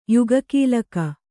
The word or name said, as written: ಯುಗಕೀಲಕ